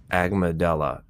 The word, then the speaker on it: amygdala: I always pronounce this word wrong. Amygdala. You can look it up. It's not medulla, the medulla. It's amygdala, which actually affects your fear mechanism, decision makings, like the frontal cortex.